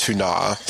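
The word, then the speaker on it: Túna: Well, I think in, you know, it's pronounced Tuna. So, but it's still. I think that's how that, you know, if you're pronouncing it based on the actual language, that's what it would be.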